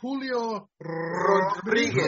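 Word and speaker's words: Julio Rodríguez: Hey, William, you have to pronounce it properly. Julio Rodriguez.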